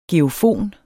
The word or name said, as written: geofon